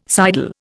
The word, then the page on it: sidle: Indian